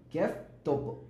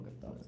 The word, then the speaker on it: Kev Topo: Este, pero lo que pasa es que yo siempre en las canciones como que cuando digo mi nombre, pues, como que se escucha como si lo dividiera, porque digo, Kiev Topo, babe, siempre. Pues pareciera. Por eso se escucha la V pronunciada, pero en verdad es Topo, Kev Topo.